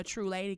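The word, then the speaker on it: Tru Lady: I think that's what went into me making my name a True Lady, because I don't spell it T-R-U-E. I spell it T-R-U. And I was like, you know, to me, the E is like the halo accented over the U,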